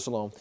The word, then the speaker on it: Siloam: That would be from the Gion Spring to the reservoir, which is the Pool of Siloam. Is that what I say? Did I say that right? Yeah, Pool of Siloam.